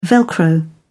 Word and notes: velcro: British English

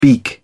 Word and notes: beak: British English

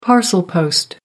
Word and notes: parcel post: American English

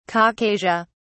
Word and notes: caucasia: British English

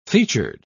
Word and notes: featured: British English

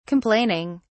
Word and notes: complaining: British English

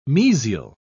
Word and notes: mesial: British English

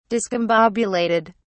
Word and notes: discombobulated: British English

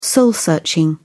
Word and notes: soul searching: British English

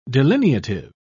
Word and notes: delineative: British English

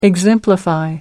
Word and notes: exemplify: American English

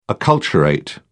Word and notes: acculturate: British English

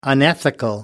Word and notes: unethical: American English

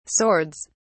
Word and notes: swords: British English

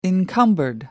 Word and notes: encumbered: British English

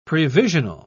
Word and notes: previsional: British English

